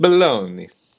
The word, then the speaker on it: Bologna: So like there's a town in Italy right now, for instance, I think it's Bologna is how I pronounce it. I'm probably mispronouncing it in Italian. But Bologna.